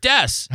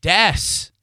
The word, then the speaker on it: Dees: Rick Dess. – How many? Could you spell it? – R-I-C-K. – And the last name would be? – D-E-E-S. – And that's pronounced? – Rick Dess. So there's your answer.